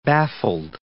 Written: baffled

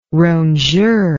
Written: rongeur

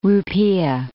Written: rupiah

